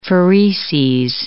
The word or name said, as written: phereses